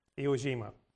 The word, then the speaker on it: Iwo Jima: Aojima? Yeah. Iwo Jima.